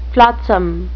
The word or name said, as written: flotsam